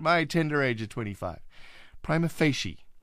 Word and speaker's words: prima facie: It's not prima facie either. The correct pronunciation is prima facie. Prima facie. Prima meaning first word prima, second word facie.